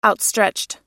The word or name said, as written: outstretched